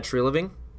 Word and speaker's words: Treliving: Next, I have Brad Treliving from the Flames. Is that how you say his name? – Yes.